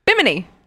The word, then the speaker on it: Bimini: Over the next 4 days, Harvey piloted the Bluebell east towards the tiny island chain of Bimini? I think that's how you say it? Bimini? – Bimini.